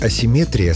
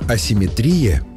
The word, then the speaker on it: асимметрия: Вариант асимметрия словарями не поддерживается и считается ошибочным. Так что в русском языке симметрия есть, а вот асимметрии нет. Запомнить легко. Понятие «симметрия» — это когда все ровно и по центру, даже ударение стоит по центру слова. А вот асимметрия — уже с нарушением баланса, поэтому и ударение съезжает к концу.